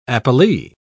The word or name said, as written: appellee